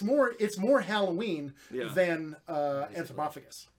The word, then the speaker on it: Anthropophagus: Anthropophagus.